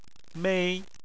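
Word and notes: mei1: tone 1

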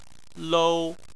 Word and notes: lou: 1

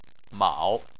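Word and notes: mao3: tone 3

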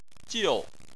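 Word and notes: jiu: tone 4

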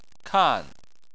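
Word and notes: kan: tone 4

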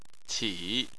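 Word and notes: qi: tone 3

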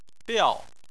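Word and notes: biao: tone 4

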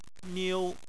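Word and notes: niu: tone 1

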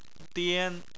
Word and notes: dian: 1

